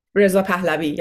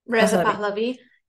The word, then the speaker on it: Reza Pahlavi: Reza Pahlavi, yeah. – Reza Pahlavi.